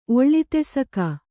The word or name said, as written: ಒಳ್ಳಿತೆಸಕ